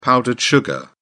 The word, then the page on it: powdered sugar: British English